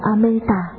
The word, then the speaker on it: Amita: Chữ A-di-đà là tiếng Phạn Rama, ở Ấn Độ nguyên âm đọc là Amita,